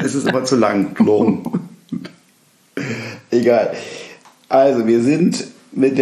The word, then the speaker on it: Toulon: Toulon.